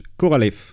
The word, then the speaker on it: Korolev: Et plus précisément par l'ingénieur, alors attention, Sergei Pavlovitch Korolev. – Ah oui. – Je le prononce bien. Hein. – Ouais, très bien.